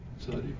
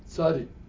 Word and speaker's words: Tzadi: What is the name, how do you pronounce the letter before kuf? What's the letter? – Tzadi. – Say again? – Tzadi.